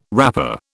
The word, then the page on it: wrapper: British English